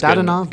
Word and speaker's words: Dadonov: Evgeny Dadonov. Is that how I pronounce yeah, it? that's Dadonov. Good, good Dadonov, enough.